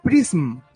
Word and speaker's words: PRISM: Prism. Así se pronuncia.